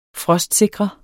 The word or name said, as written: frostsikre